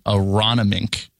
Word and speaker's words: Aronimink: It's at, and I've had to look up how to pronounce it several times, Aronimink.